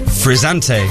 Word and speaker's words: frizzante: Frizzante.